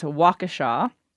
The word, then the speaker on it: Waukesha: The correct pronunciation is Waukesha.